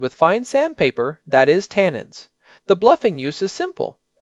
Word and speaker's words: tannins: First word, tannins. I mentioned them earlier. Pronounced T-A-N. Ends.